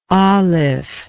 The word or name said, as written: alif